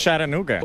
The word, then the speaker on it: Chattanooga: Chattanooga. – Chattanooga. – En español, Chattanooga. – Es Chattanooga. – Una clase rápida de cómo decir la localidad que nadie sabe decir en este país. – Chattanooga.